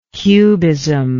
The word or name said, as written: cubism